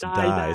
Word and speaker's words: dai: and the word is spelled D-A-I, but it's pronounced die.